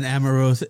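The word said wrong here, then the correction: Amouranth: Amaranth is the top Twitch streamer that makes millions, $1.5 million a month doing ASMR work.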